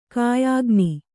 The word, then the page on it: ಕಾಯಿಗಣ್ಣು: Kannada